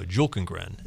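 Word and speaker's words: Bjelkengren: Julkengren is how you pronounce it.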